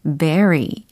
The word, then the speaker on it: bury: B, U, R, y 라는 철자인데 요거 발음이 그죠? berry 라고 하셔야죠. berry. berry 아니고 b e r y 아닙니다. 어, very도 아니에요.